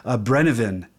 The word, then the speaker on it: Brennivín: What they're known for, it's something called a Brennivín, and I can't roll my R, but when I looked up the pronunciation, that R is really rolled.